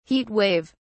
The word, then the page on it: heat wave: British English